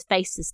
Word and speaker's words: PHACES: is facies syndrome. Facies is spelt P-H-A-C-E-S, and that's a mnemonic,